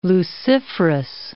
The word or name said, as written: luciferous